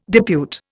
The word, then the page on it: depute: Indian